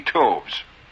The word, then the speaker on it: toves: how to pronounce Slithy Toves. The I in Slithy is long, as in writhe, and Toves is pronounced so as to rhyme with groves.